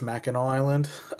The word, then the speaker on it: Mackinac Island: Have you been to Mackinac Island? – Okay, so I'm going to get killed if I don't correct you and say it's Mackinac Island.